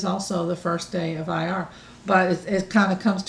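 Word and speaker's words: Iyar: If somebody would like to have the calendar for May, or it's the month of IR, is that how you pronounce it? – Mm-hmm.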